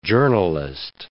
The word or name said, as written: journalist